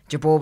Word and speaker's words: Jabour: And the celebrant was an old friend of my dad's, and he knew Jabour was the proper pronunciation, but Jabour's what we used. And I told him to use Jabour because, you know, that's the name I grew up with.